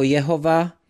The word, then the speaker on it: Jahve: Někteří tento výraz vyslovují jako Jehova. Pravděpodobně se vyslovoval Jahve, ale nikdo to stoprocentně neví.